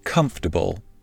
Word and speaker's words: comfortable: The word comfortable is often mispronounced. Many non native English speakers pronounce it with four clear syllables for bull. However, most native English speakers pronounce it with only three syllables and an emphasis on the first syllable comfortable.